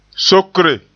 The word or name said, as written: sokre